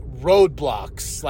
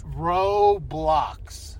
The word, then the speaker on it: Roblox: Roblox.